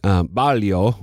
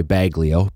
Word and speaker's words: Baglio: There was a book that came out a few years ago by a journalist, Matt Baglio, would be the Italian way to pronounce his name, but it's probably Baglio, B-A-G-L-I-O is probably how he says it.